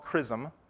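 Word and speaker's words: XRISM: It is now called CRISM. That's how you pronounce it, by the way, since people have asked.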